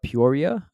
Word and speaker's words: Peoria: The next one we do have is Peoria, I believe.